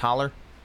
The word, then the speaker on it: Hollow: I think it's pronounced holler.